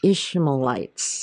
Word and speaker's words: Ishmaelites: Then they lifted their eyes and looked, and there just so happened was a company of Ishmaelites.